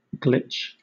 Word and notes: glitch: British English

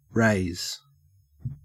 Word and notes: raze: Australian English

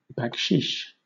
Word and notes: baksheesh: British English

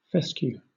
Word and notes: fescue: British English

